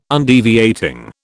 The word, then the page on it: Undeviating: British English